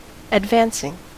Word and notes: advancing: American English

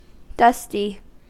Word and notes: dusty: American English

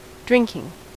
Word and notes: drinking: American English

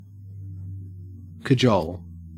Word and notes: cajole: Australian English

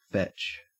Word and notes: fetch: Australian English

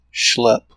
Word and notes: schlep: American English

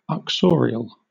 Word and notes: uxorial: British English

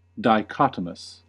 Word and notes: dichotomous: American English

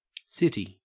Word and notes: city: Australian English

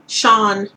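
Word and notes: shone: Australian English; Canadian English; British English

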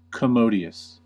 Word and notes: commodious: American English

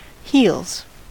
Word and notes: heels: American English

